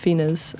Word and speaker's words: Fina: Her voice slightly reminds me of Fina's, so I'm going to play this version of Days Incomplete. – Did anybody else know that the artist that looks like the word fine is how you say Fina?